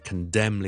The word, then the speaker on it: condemn: 小叔就成日都聽到係有啲人讀得麻麻地嘅，就係、是、 silent n 唔讀嘅 n 音，最常見嘅咧就係 condemn 呢個字，c o n d e m n，condemn 譴責一啲嘢，I condemn this，OK，condemn，咁嗰個就唔使讀 condemn，唔使 n 翻出嚟嘅，就咁讀 condemn 就可以啦。